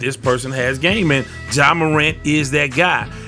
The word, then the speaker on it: Ja: And I said Jay twice, I think, and then I cleaned it up and said Ja. So it's pronounced Ja Morant.